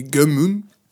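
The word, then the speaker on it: Gunnm: Alors, Alita, Battle of Angel et l'adaptation du manga Gum. Alors, la prononciation européenne, ma prononciation japonaise, c'est Gumun.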